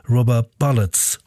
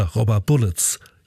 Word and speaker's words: Rubber Bullets: Und jetzt gibt's die B-Seite vom Hit Rubber Bullets oder Rubber Bullets. Jetzt habe ich es richtig ausgesprochen.